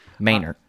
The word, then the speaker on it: Manor: Well, I'm always partial to Manor, Texas, who on its sign is spelled Manor. – M-A-N-O-R. – Yeah. Manor. It's Manor.